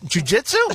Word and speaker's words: jiu-jitsu: How did he lose his beautiful wife to a jazoo construction? How you pronounce it? What's the word? I don't even know. What is it? – What? Jiu-jitsu? – Jiu-jitsu instructor.